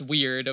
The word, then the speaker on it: wyrd: W-Y-R-D. – Oh, yeah, that's a good point. I didn't even think of that. I mean, because it's pronounced weird, of course, but as we know.